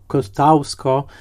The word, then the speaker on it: Kościuszko: No właśnie, jeżeli jacyś producenci nas słuchają, to pamiętajmy, że Kościuszko, co prawda wymieniane, wymawiane jako Kotausko,